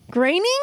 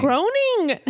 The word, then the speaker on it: Groening: Graining? – What have you been saying? – Groaning.